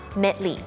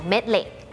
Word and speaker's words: Medley: อย่าง Medley เนี่ยก็เป็นอีกตัวอย่างหนึ่งเหมือนกันนะคะที่มันออกเสียงว่า Medley ไม่ออกเสียง Medley LEY เหมือนกันนะคะแต่ไม่ออกเล่ออก Lee